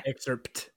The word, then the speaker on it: excerpt: Oh, so it does have a T or does have a P? – Google pronounces it pt. – Okay. – Excerpt.